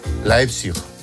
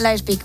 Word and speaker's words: Leipzig: Leipzig. – Leipzig. – Leipzig, ¿ves? – Leipzig, muy bien.